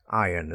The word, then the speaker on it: iron: Yeah, the R is very soft. Eh? Iron. It's almost like I-O-N, not I-R-O-N.